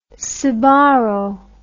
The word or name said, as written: Sbarro